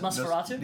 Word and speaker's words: Nosferatu: I just watched how do you pronounce it? – Nosferatu? – Yeah, that's it.